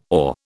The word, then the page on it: awe: British English